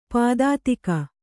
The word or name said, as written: ಪದಾತಿಕ